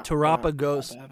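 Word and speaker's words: Terapagos: But that feels like Terrapagos.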